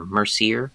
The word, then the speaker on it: Mercier: His name is Leo Mercer, and most people I knew have pronounced his last name Mercer, John, but I've also heard people pronounce it Mercier.